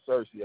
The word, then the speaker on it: Searcy: My first name is Searcy. That's spelled S-E-A-R-C-Y. Last name is Anderson, A-N-D-E-R-S-O-N.